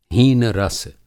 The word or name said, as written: हीन-रस